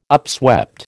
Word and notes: upswept: American English

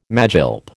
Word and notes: magilp: American English

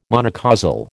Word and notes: monocausal: American English

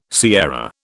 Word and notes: sierra: British English

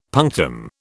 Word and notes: punctum: British English

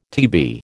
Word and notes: TB: American English

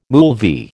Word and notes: moolvi: American English

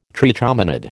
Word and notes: trichomonad: American English

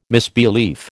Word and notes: misbelief: American English

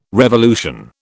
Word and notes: revolution: British English

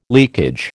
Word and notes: leakage: American English

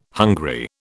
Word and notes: hungry: British English

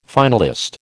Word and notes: finalist: American English

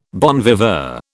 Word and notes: bon viveur: British English

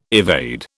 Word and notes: evade: British English

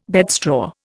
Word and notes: bedstraw: Indian

